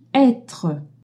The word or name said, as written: etre